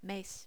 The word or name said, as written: mes